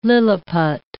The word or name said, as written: Lilliput